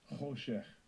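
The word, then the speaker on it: Hoshech (חֹשֶׁךְ): Darkness, Koshek. Let's hear the pronunciation. – Strong's H2822. Hoshech. Hoshech.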